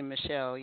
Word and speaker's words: Michelle: Her name is Michelle Prince. – Michelle. Mm-hmm. – Yep. – Oh, yeah. – It's spelled like Michael, but it's actually Michelle, yeah.